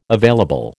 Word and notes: available: American English

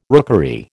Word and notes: rookery: American English

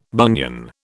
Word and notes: bunion: British English

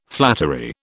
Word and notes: flattery: British English

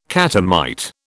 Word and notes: catamite: British English

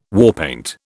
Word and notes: warpaint: British English

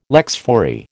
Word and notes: lex fori: American English

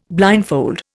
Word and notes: blindfold: Indian

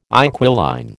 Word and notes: inquiline: American English

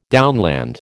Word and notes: downland: American English

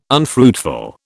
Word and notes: unfruitful: British English

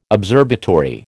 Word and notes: observatory: American English

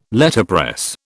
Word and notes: Letterpress: British English

